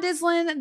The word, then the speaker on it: Dislin: We have author John Dislin, and in his notes, I love that he wrote, it's pronounced like sizzlin'. I laughed out loud when you said that. – That's funny. – We have John Dislin, the author of Nehemiah Strong.